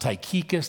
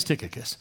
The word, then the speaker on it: Tychicus: So Tychicus, sometimes his name is pronounced Tychicus. That's not in the original, but most people don't read the original anyway, and who cares? His name is Tychicus.